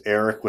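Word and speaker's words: Erik: So, again, my name is Eric J. Olson. It's Eric with a K, and Olson is spelled Olson.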